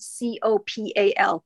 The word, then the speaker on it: copal: And it's spelled C O P A L Copal.